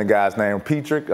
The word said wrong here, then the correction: Pritchard: am I saying it right? – Hey, Pritchard? – Pritchard. – Yeah. Pritchard, I'm Pritchard.